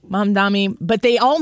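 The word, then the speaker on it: Mamdani: They call him Mom Dommy a lot. They can't get his name right. It's like Mom. Your mother, whose name is Donnie. It's Mom Donnie.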